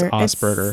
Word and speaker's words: Asperger: The difference though, was that's how it's pronounced, not Asperger. – No, I mean, that's how the guy read Asperger. It's Asperger.